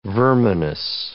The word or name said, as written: verminous